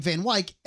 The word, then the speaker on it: Van Wyck: Also, they were pronouncing the Van Wyck Expressway, the Van Wyck. – Right. – Even though it was a guy named Van Wyck, everyone calls it Van Wyck.